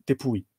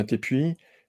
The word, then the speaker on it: Tepui: Alors, la prononciation que j'avais entendue, c'était Tepui.